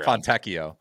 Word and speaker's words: Fontecchio: I'm going to mispronounce this name, but wouldn't Fonticchio help me out here? – Fontecchio. – Tecchio.